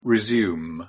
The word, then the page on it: resume: English; verb